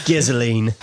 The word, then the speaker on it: gasoline: The best part, secretly, of every single Mad Max movie is the way Australians pronounce gasoline, by the way. Gizzoline.